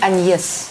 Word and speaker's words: Agnès: Есть форма на французском языке. Пишется Агнес. Читается Аньес. – Je m'appelle Agnès.